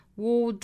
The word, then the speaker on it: ward: The only way to 弱读成 t, ward off, 连读 ,ward off, 注意 ward 这个单词的元音啊，应该不卷舌的 ,ward off,